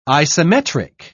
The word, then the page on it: isometric: British English